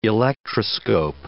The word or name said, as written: electroscope